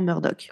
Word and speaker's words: Murdaugh: Alors ça s'écrit Murdo, mais ça se prononce vraiment Murdoch.